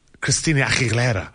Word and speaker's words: Christina Aguilera: I never liked the way she said her Christina Aguilera. I know that's how you pronounce it.